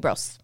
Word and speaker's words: Liebross: Liebross.